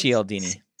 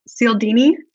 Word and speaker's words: Cialdini: I think it's Cialdini. – Cialdini.